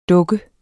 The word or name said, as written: dukke